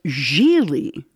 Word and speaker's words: Gigli: And well, it was Gigli. The other G is silent.